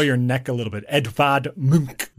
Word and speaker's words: Edvard Munch: Yeah, I had to look up a pronunciation for Eduard Munch, a famous painter of The Scream, because his name looks like Edvard Munch. – But it's Edvard Munch.